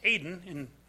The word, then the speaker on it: Eden: A Garden of Eden. The word Eden actually means, it's pronounced Aden in Hebrew.